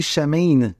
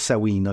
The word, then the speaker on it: Samhain: Vous voyez, je me J'essaie de dire Sawin, mais j'en reviens toujours à ne C'est pas pourquoi. Ben, peut-être parce que ça s'écrit S-A-M-H-A-I-N, Sam, Ain. Bon, bref, euh, ça ne concerne que moi, alors mais on va dire Sawin, OK?